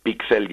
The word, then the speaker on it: píxel: Eh, pixel ya se adaptó al, al español, eh, Pixel o Pixel, hay sitios donde se pronuncia aguda.